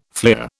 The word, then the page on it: fleer: British English